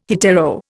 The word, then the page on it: hetero: Indian